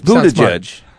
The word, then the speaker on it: Buttigieg: So it's Buttigieg.